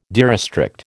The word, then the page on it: derestrict: American English